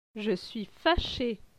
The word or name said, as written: Je suis fache